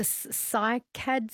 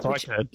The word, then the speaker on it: cycad: So like the, is it the cycad seeds? I'm probably not pronouncing this right. – Cycad. – Yeah, cycad, is that right?